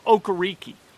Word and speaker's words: Okereke: How about Bobby Okereke, or Okereke, which is what he told me his name is pronounced. Okereke. He's been healthy.